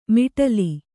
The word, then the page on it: ಮೊಟಲೆ: moṭale